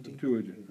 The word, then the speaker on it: Septuagint: Septuagint. – Septuagint. – Septuagint. – Septuagint.